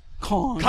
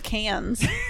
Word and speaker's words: Cannes: Is it pronounced Cannes? – Cannes. – Cannes! – I didn't know that. I thought it was cans.